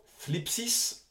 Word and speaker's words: thlipsis: Le nom thlipsis, alors tu vois, c'est à prononcer comme si tu as un cheveu sur la langue, entraîne-toi, c'est rigolo, est traduit par tribulation, tourment, détresse, pression, oppression, affliction.